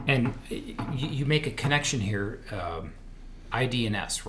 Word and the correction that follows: DE&I: That's what we had to, is that, did I say that right? – DE&I. – DE&I.